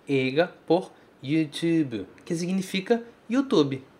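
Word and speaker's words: YouTube (ユーチューブ): É assim que o japonês pronuncia YouTube, ok? Tu, esse tu é importante e o bu no final, certo? Em português, a gente fala bi, YouTube, como se tivesse um i no final.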